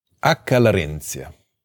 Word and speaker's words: Acca Larentia: Am I pronouncing that correctly? – Acca Larentia.